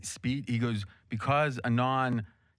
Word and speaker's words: Anand: Anand, Anand, yeah, is how you say.